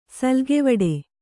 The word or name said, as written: ಸುಲಿಗೆವಡೆ